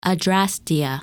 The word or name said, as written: Adrastea